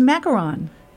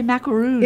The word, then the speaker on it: macaron: And I think it's macaron. – I would say macaroon. – It's spelled here M-A-C-A-R-O-N. – Only one O? – Only one O.